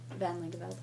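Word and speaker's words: Van Langeveld: My name is Elisa Van Lingeveld. I live in Foxborough. – Can you say your last name again? – Van Lingeveld.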